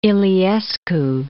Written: Iliescu

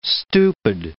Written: stupid

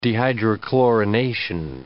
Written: dehydrochlorination

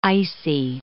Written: icy

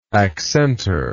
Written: accentor

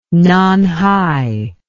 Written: Nanhai

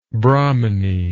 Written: brahmani

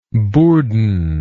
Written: bourdon

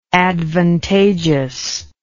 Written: advantageousness

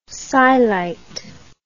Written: scyelite

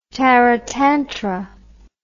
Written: taratantara

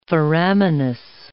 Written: foraminous

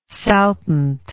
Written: saltant

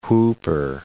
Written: whooper

